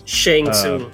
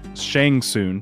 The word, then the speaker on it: Shang Tsung: taking on Shang Tsung, or how do you say it? Crap, I can't say these names. – Shang Tsung. – Shang Tsung, thank you.